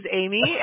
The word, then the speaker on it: Amie: Listen, my name's Amy, and it's spelled A-M-I-E, and I say the exact same thing, the only way.